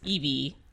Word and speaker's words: Eevee: But those are evidently, I'm going to pronounce this Eevee. E-E-V-E-E.